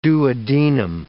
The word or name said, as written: duodenum